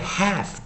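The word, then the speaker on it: half: 好，所以注意一下，当你在念 have 的时候啊，l 是不发音的哈。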